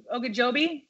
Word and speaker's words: Ogunjobi: Ogunjobi? – Ogunjobi.